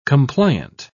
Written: compliant

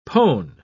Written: pone

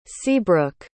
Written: seabrook

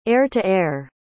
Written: air-to-air

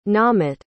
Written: naamit

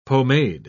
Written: pomade

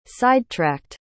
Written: sidetracked

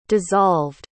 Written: dissolved

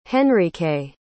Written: Henrike

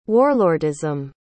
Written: warlordism

